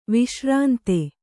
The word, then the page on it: ವಿಶ್ರಾಂತ: viśrānta